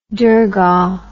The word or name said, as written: durgah